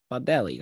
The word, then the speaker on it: Baldelli: Did you really just butcher his name, former Sox player? – Yeah, probably. I don't know. I'm reading it off. – Rocco Baldelli? – Baldelli. That's what I said.